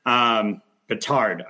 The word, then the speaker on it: Bedard: They jumped up from third to first, which unfortunately for the Ducks, knocked them out of the Jared Bednar or Connor Bednar series, rather. Bedard, I got to say that right. Connor Bedard. Yep. There's no N in there. I want to make it sound like the coach.